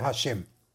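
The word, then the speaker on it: השם: Y deberá meditar el santo nombre de la del nombre a Hei Shin Mem, que se pronuncia Hashem.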